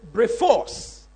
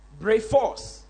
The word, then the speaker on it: brephos: That word newborn babes, that word babes there is brephos. Brephos.